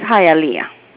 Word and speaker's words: Hialeah: This is Hialeah. – Hialeah. Ah, okay. I was so curious. It's a beautiful name of a city, but I just I couldn't figure it out. I was like Hialeah.